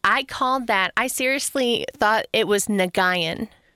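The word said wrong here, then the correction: Nguyen: I called it Nagayan for 5 years in my job. – Before somebody finally corrected you? – Before somebody's like, you know, that's pronounced Wynn, right?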